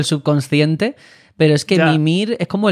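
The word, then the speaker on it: Mímir: Pues a veces pronuncian su nombre como Mimir y otras Mimir. La verdad, y los mismos personajes lo pronuncian Mimir, Mimir. Bueno, es que no se acaban de aclarar, no tendrían una indicación clara. Cory Barlog en las entrevistas le llama Mimir.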